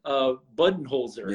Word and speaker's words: Budenholzer: Then, of course, Vogel, like I said, and then Budenholzer.